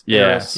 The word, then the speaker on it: Heiress: Like heir to the throne. – It's Heiress. Yeah. – Yeah. I know this in my head, but then my mouth says, I'm just going to pronounce the H.